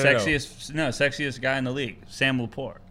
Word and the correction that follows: LaPorta: He's not even saying his name right. – What's his name? – Sam LaPorta.